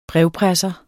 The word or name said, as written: brevpresser